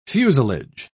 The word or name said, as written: fuselage